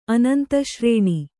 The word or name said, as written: ಅನಂತಶ್ರೇಣಿ